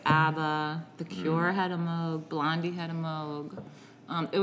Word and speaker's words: Moog: A Moog is, it's not Moog, as many people think it's supposed to be pronounced. It's Moog, M-O-O-G, which is a synthesizer.